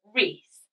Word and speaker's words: Rhys: So the next name I picked, because it looks really nice written down, is the name Reese. But I like the spelling R-H-Y-S, which is the traditional Gaelic spelling, but she's in the US, they maybe spell it R-E-E-C-E, Reese. I think Reese Gonzalez sounds really, really nice.